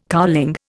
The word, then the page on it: cowling: Indian